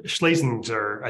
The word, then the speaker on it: Schlesinger: Yeah, I was really into Arthur Schlesinger's book when I was in college, or Schlesinger, I think is how you actually pronounce his name, son Schlesinger.